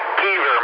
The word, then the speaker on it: Keever: Keever, my last name.